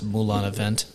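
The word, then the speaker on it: Moulin-à-Vent: I think it's pronounced Moulin Avant. – Yes.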